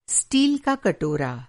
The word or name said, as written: स्टील